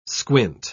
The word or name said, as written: squint